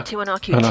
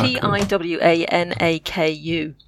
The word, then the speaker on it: Tiwanaku: Tiwanaku. T I W A N A K U.